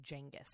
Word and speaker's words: Genghis: Because I remember they were pronouncing it Genghis. – Oh, and yeah, because that is the correct way to pronounce it.